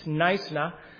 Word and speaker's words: Knysna: The region we're going to be in is in a town called Knysna. It has a K, but it's a silent K, and so it's kind of pronounced as you would tell somebody to be nice. It's Knysna.